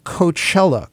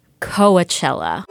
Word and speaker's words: Coachella: No, there may have been some episodes where you said it a little more like Coachella. The festival business has gotten so big uh, with the likes of Coachella.